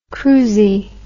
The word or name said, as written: crusie